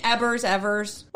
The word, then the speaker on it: Ebbers: The CEO of WorldCom, Bernard Ebbers. Spelled with B's. I watched a bunch of content on it and thought his name was Ebbers with a V for at least 4 hours.